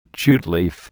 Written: jute leaf

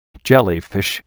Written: jellyfish

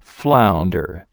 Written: flounder

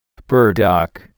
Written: burdock

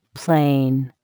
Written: plane

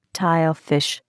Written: Tilefish

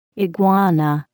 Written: iguana

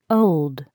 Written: old

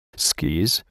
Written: skis

